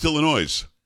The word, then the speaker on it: Illinois: Thinks it's pronounced Illinois. – No. – That state next to Indiana is Illinois. You don't pronounce the S. – This is silent.